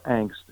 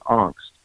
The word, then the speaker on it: angst: So I basically, during that period of about 2 or 3 weeks, was experiencing what the existential philosophers call angst, or angst, if you want to use the Midwestern American pronunciation. But the Germans pronounce the word angst, A-N-G-S-T, and it means anxiety about one's future being so radically uncertain, anxiety about one's own future being radically uncertain.